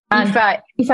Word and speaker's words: Aoife: And I think I've nailed, so it's Aoife? – Aoife and Tag? – Tig.